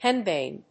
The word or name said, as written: henbane